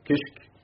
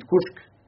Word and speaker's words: Kishk: Quelle serait sa parole s'il avait écouté les sermons de Al-Qustas, les narrateurs de récits de notre temps comme Abdelhamid Kouchk, disait Abdelhamid Kishk ou Kishk, la réalité c'est Abdelhamid Kouchk, c'est comme ça qu'il faut le prononcer,